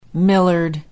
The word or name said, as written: Millard